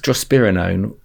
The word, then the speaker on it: drospirenone: Yeah, so this is drospirenone, or SLYND, S-L-Y-N-D.